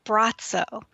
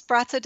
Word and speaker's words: Braco: Many of you may have already heard of Brazzo, who's known as the Gazer. His name is spelled B-R-A-C-O, and he has provided healing, hope, and comfort to people around the world solely through his gaze.